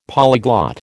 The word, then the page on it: polyglot: American English